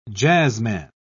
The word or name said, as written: jazzman